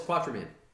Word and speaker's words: Quatermain: Oh, it's Quatermain. – Oh, well, that's how you pronounce it. Oh, screw me. – No, it's not spelled. It's not pronounced how it's spelled, so I totally get you.